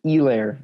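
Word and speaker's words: Helaire: Clyde Edwards, Elair, the H is silent.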